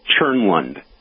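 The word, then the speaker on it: Tjernlund: Yep, it's pronounced Cherlin. So the TJ at the beginning sounds like a CH with Cherlin Products.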